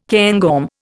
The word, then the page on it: cairngorm: Indian